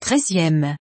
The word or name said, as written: 13eme